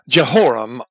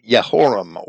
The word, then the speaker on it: Yehoram: Either as the initial element or in the form, Jeho or Yeho or Yo, J-O, Yo, as in Jehoram or Joram, or properly Yehoram or Yoram.